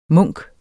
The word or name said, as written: munk